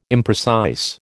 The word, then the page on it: imprecise: American English